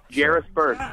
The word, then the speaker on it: Jairus Byrd: Well, how did you say that free agent name? We're still getting it wrong after all this time. – Man. – We should know by now. – Jairus Byrd. – Yes.